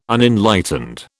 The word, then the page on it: Unenlightened: British English